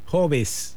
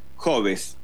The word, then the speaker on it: Hobbes: Se escribe H-O-B-E-E-S, Hobbes. – Sí, Hobbes, Thomas Hobbes, hay 8.